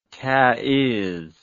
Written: taizz

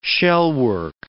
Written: shellwork

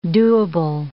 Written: doable

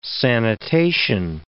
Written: sanitation

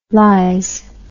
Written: lyze

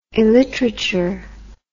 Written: illiterature

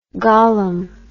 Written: gollum

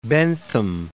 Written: Bentham

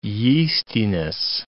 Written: yeastiness

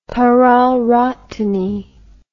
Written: pararhatany

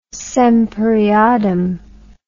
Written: semper eadem

